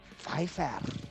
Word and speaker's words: Pfeiffer: At number 3, 62 years old from Santa Ana, California, we have Michelle Pfeiffer.